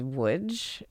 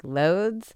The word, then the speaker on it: Łódź: When we read it in English, it looks like Lodz. I also hear Lodz a lot. So I don't know that I would call myself an expert on that. But I've been told that in Polish, it's Łódź.